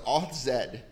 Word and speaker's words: Authzed: Authzed.